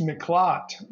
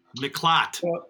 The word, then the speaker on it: Miklat: It's Miklat, which means haven or refuge. – Miklat.